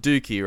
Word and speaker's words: Dookie: It's pronounced Dookie, right? Yeah. Yes. Dookie. Yes. What, classic. What else would you? What's the alternative? Dookie. – Dookie. Doki.